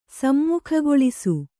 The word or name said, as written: ಸಮ್ಮುಖಗೊಳಿಸು